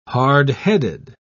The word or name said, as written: hardheaded